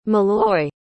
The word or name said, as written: malloy